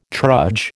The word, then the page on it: trudge: American English